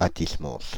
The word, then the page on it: Athis-Mons: [at̪is mɔ̃s]